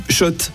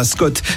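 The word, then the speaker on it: Schott: Oui, Shot et pas Scott, ça fait des années qu'on prononce mal la marque.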